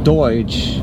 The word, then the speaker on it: Deutsch: No, in the German language, Deutsch, which sounds like Dutch, but Deutsch, that means German.